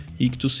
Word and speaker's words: Ichthus: Ictus se escreve I-C-H-T-H-U-S.